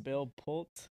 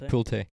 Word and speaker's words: Pulte: Bill, is it Bill Pult? – I don't know how to say his name. Bill Pulte. He's I think it's Pulte. – Pulte? Yeah. – Pulte. I don't know. Pulte. Just say it.